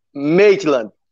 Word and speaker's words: Maitland: É, Midland? É, é, Maitland. Maitland mobilização neural e também neurodinâmica clínica.